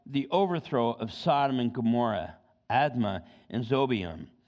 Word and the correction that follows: Zeboim: of which the Lord overthrew in his anger and his wrath. Now, Admah and Zeboim is actually how you pronounce it.